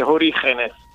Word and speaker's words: Orígenes: Si chiama Orígenes, per chi dà la spagnola, con le poche ore di spagnolo che ho fatto all'università, ho scoperto che la G si legge. – Si dirà, devi aspirare. – Sicuramente Orígenes.